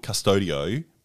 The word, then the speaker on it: Qustodio: We put in a new program called, it's called Custodio, but it's spelled Qustodio, which basically is our monitoring system for her.